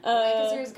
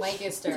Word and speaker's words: Lancaster: Well, how do you say this name? – Lancaster. You say Lancaster. – Lancaster. – In South Carolina, most people do. There's some people who say Lancaster. – I feel like that is what I wanted to say. – Yeah, I think it's more commonly pronounced Lancaster. – Lancaster. – Yeah, it's like more southern to say Lancaster. – I don't know. Lancaster. I can't say it the way you said it.